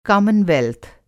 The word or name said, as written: कॉमनवेल्थ